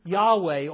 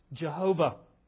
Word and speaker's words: Yahweh: But now David in verse 7 changes from the one use of El to Yahweh, or as it's sometimes pronounced, Jehovah.